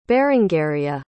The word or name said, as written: berengaria